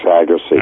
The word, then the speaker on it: Agase: So, how do you pronounce that last name? A-G-A-S-E, that was at Northwestern and... – Alex Agassi. – Agassi, okay. March 27th, and then two guys I wanted to focus on because we both are very familiar with them.